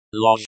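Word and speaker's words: loj (ложь): Pronounced loj. The zh sound is like the s in pleasure.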